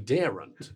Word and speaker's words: daren't: And that made us laugh because we daren't. – Yeah, that's right. – Two syllables.